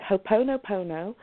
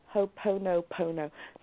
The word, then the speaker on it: Ho'oponopono: So it's Ho'oponopono. It's spelt H-O-O-P-O-N-O. Ho'oponopono. So it's P-O-N-O-P-O-N-O. And it's H-O-O-P at the beginning.